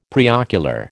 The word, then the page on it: preocular: American English